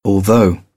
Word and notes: although: British English

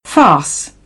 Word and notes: farce: British English; ɑː

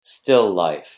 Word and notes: still life: noun

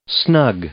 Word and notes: snug: adjective

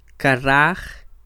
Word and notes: Karaj: Quiché

